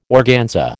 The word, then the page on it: organza: American English